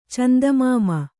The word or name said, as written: ಚಂದಮಾಮ